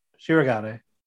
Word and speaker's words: Shirogane: Kaguya overheard Shigeron talking about... – Why do you say Shirogane? – Yeah, it's his name. Shirogane. – Shirogane. You gotta say it properly, goddammit.